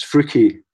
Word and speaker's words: Freuchie: The cricket team from the small town or large village, how do you say the name? Is it Freuchie? – Yes, it's Freuchie. Freuchie.